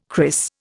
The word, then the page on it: cris: Indian